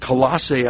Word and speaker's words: Colossae: The church at Colossae, I should pronounce it.